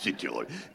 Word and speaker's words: Südtirol: Man betont Südtirol auf dem Ü, auch wenn es nicht in der Türkei liegt. Es ist Südtirol.